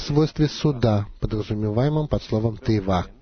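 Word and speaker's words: тейва (תיבה): При этом конечная буква «гей» не произносится в слове «тейва» — «ковчег».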